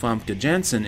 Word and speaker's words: Famke Janssen: But it also kind of had, with the addition of Famke Janssen in here, you kind of get to see Logan's conscience at work. There we go. I said it right that time.